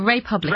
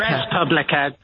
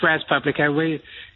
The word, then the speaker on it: Respublica: Is it Respublica or is it Republica? – Respublica, just Respublica.